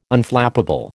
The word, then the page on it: Unflappable: American English